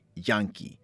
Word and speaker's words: yanqui: and that is spelled Y-A-N-Q-U-I, Yankee.